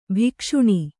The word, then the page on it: ಭಕ್ಷಣೆ: bhakṣaṇe; Kannada